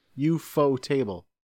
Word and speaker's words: ufotable: I think, if anything, it's ufotable, right? If it's Japanese. – All I know is it's not UFO table. – UFO table. UFO table.